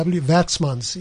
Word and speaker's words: Werksmans: Worksmans is spelled WERKSMANS. – Yes. – Works, Vatsmans in Afrikaans. – Yes, yeah. That would be the Afrikaans version of it, yes.